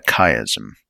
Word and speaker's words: chiasm: Is it chiasm or chiasm? No, let me double check here. Yeah, chiasm. Joining the optic chiasm.